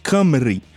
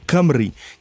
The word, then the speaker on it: Cymru: Ele se chamava originalmente Camry, é, que se escreve C-Y-M-R-U, sendo que o Y tem um som de A meio fechado e o U tem som de I é, Camry, que significa algo como fellow countryman, né?